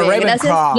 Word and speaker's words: Ravenclaw: Yo, o sea, hace un toque estábamos hablando y a mí me han dicho que podría ser como Luna, así como River Clown. ¿Cómo se pronuncia? Ravenclaw. – Ravenclaw. – No, Ravenclaw. Gracias, por ayudarme con la pronunciación.